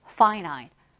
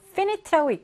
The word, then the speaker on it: finite: finite, finite. 여러분들 이거 finite라고 읽지 않도록 유의하셨으면 좋겠고요.